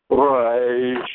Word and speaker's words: resh: The closest we have to the double sound of the letter resh is in Spanish, when you pronounce the R sound as a rolling sound. Raish, raish, but as close as you get to that, even if you speak Spanish as your first language, it's not quite accurate.